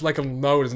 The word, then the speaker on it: Lecuona: I'd rather Dixon than I can't even pronounce Lecuona's name. Lecuona. Sounds like Macca Masharona.